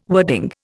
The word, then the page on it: wording: Indian